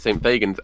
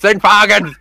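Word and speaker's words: St Fagans: I got shouted at by an old woman at a bus stop in Cardiff because I mispronounced it. I called it St. Fagin's, and this woman just went, "It's St. Fagin's!"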